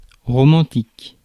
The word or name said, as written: romantic